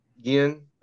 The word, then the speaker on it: Gallen: If you pronounce Gallen? I'm not sure. – Gallen, you're right.